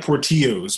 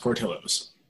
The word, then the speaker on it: Portillo's: It looks like Portillo's, but it is pronounced Portillo's.